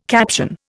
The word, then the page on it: caption: Indian